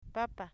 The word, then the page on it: papa: 'papa